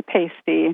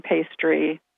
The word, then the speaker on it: pasty: There's also an entry for the card game Euchre, and as librarian explains, a favorite baked good spelled P-A-S-T-Y. – Don't say pasty, don't say pastry. It's definitely pasty.